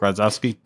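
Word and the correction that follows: Grodziskie: Did I just say that wrong? – Grudziski.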